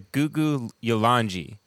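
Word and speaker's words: Kuku Yalanji: So remember how he told us the K's are pronounced with a G? Mm-hmm. So I looked this up. They're the Gugu Yolanji.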